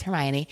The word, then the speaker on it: Hermione: So now we all know that it's Hermione, but so it's, we're just going to call her Eve in this situation.